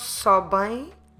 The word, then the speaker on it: sobem: Make sure when you pronounce sobem, this diptong ein, make sure, it's sorry, not a diptong, this syllable ein, it's a nasal sound, but it's not a diptong, I'm sorry. So eles sobem, make sure you leave your mouth open. Don't close your lips to pronounce ein.